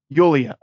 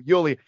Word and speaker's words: Yulia: I'm going to mispronounce her name like three times. Help me out. – Yulia. – Oh, there we go. Yulia.